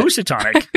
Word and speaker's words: Housatonic: I keep asking how to pronounce this. – But Housatonic.